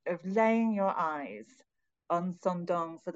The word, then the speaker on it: Sơn Đoòng: Well, it seems that a lot of Americans seem to pronounce it Son Doon, but the locals pronounce it Son Dong. So that's what I tend to go with.